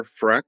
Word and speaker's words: Freck: My name is Peter Freck. That's spelled F-R-E-C-K.